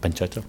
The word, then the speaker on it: pancetta: In fact, I have a chicken and waffles made with polenta and pancetta at my restaurant, so waffles. – I'm sorry. How did you say that? Pancetta? – Pancetta.